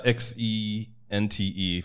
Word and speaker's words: Xente: Also, it's an interesting way you spell it. Uh, X E NTE. For those who don't know, sente is a Luganda word that means money, right? – Yeah.